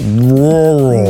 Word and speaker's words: rural: So rural. It's got the double R, so it's like rural.